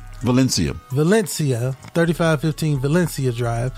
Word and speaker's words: Valencia: It's V A L. E N C I A. Val- Valencia. there you go.